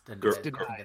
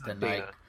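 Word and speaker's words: Danai: I think it's Denai. But yeah. – What? – Yeah. It's Denai. It's Denai.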